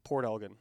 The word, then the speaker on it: Port Elgin: How do you say your hometown? – Port Elgin. – Elgin. – Yeah, the Americans say Elgin a lot here.